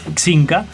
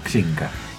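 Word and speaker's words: Xinca: Hay un ejemplo que me encanta, es una empresa mendocina que se llama Zinca se escribe con X, como Jimena con X, bueno, esto es Zinca Zinca Que ellos hicieron una marca de zapatillas, vos la veis una zapatilla común a cualquier otra, pero tiene un diferencial.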